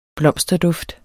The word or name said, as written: blomsterduft